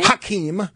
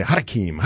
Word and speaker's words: Hakeem: I wanted to give equal time to Hakeem Jeffries. – What was his name? – Hakeem. – There you go. – What did I say? Hakeem. – You added a syllable.